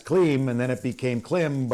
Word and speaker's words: Klim: Good guy, because we're talking about his name, and I said, you know, because it's pronounced Klim. He goes, yes, it's Klim, but really I don't care.